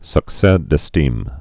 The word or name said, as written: succes d'estime